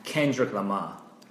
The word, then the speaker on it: Kendrick Lamar: Kendrick Lamar.